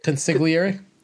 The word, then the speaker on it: consigliere: I could be the consigliere. How do you say it? – Consigliere.